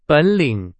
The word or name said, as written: 本领